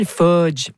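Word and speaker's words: Fuudge: Fudge ». Je dis ça comme ça parce que ça s'écrit avec deux U.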